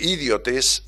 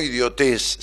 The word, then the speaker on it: idiotes: Em, era eh, idiotes, no idiotez, sino idiotes, como acentuando la, primera sílaba, es en griego aquel que se ocupaba de los asuntos públicos este, en su beneficio personal.